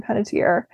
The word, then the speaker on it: Panettiere: i might be butchering her name hayden Penet- penetier Hayden Panettiere, who she was in the TV show Nashville mm-hmm.